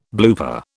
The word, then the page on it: blooper: British English